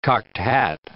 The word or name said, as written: cocked hat